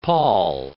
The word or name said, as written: Paul